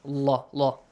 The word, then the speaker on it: Allah: The rule says, if there is a dhamma sound or a fatha sound, pronounce the letter lam in the word Allah, heavily with the law sound. Allah, Allah.